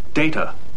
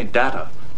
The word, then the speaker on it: Data: My name. It is pronounced Data. – Oh? – You called me Data. – What's the difference? – One is my name. The other is not.